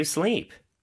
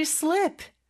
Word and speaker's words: sleep: S-L-E-E-P. – 네, 그리고 요 발음은 모음 소리가 긴 소리죠. Sleep. 만약에 짧게 How did you slip? 라고 하면 어떻게 미끄러졌어? 라는 질문이 됩니다. 그래서 sleep. 이 발음 길게 끌어주셔야 돼요.